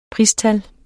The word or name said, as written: PRISTAL